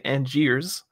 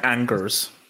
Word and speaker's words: Angers: Yeah, because for me, I know not to pronounce the S. So Angier. I'm never going to say Angiers. – Angers.